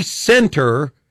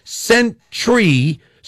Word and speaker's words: Centre: I don't say center on purpose because I'm pronouncing it sentry, so people will look up C-E-N-T-R-E.